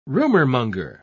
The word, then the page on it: rumormonger: British English